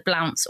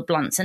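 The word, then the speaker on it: Blount: She comes from a very old family, the Blounts or the Blunts, and they pronounce it in different ways. And actually, um, descendants of the family pronounce the name in different day- ways as well.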